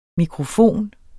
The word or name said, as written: mikrofon